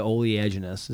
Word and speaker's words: oleaginous: I'm going to say fucking A for the oleaginous. Did I say it right? – Yes, you did.